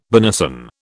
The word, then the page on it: benison: British English